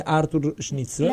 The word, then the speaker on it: Arthur Schnitzler: Se Gioia è ancora in trasmissione, ci sta sentendo, dovrebbe consigliarmi come cavolo si legge questo cognome, Arthur Schnitzler.